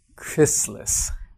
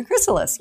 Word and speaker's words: chrysalis: From their chrysalis, yeah. – Chrysalis, yeah. I have to say it very carefully because there's no T in it. And I was pronouncing a T, but it's a chrysalis.